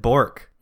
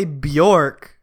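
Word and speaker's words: Björk: You're if you say Bjork, you're like pronouncing the J as a Y. – Yeah. Well, no, it's not silent. – I thought it was. – No, it, you, that's how you pronounce the J where she comes from. Is just yeah, it's Bjork. Oh.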